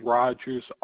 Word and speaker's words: Rogers: First name is going to be Ricky, R-I-C-K-Y. Last name, Rogers, R-O-G-E-R-S.